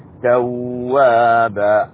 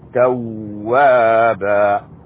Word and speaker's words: توابا: توابا الواو شددة، توابا.